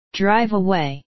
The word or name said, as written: drive away